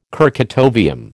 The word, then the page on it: kurchatovium: American English